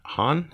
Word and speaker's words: Hahn: Catherine Hahn, is that how you say her name? – Yep.